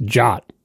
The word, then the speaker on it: JWT: It's pronounced JOT. – What? – As in J-O-T? – Because people didn't want to say JWT.